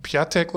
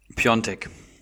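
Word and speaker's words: Piątek: Ähm, und vorne drin Piatek oder Piontek, wie wird er ausgesprochen? Da bin ich mir bis heute sehr unsicher, weißt du es? Piontek. Piontek, ne? Ist mir nämlich mhm, auch am Wochenende schon. – dann, weil ich immer die ganze Zeit Piatek gesagt habe, aber scheinbar spricht man dann äh, dieses komische A, was da angezeigt wird, etwas anders aus.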